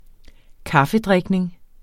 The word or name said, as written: kaffedrikning